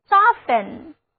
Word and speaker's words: soften: soften， s o f t e n， soften，注意字母 t 和 e 在这里不发音，单词就读作 soften，软化。